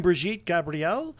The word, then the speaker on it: Brigitte Gabrielle: And we are awaiting Brigitte Gabrielle.